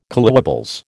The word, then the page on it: collywobbles: American English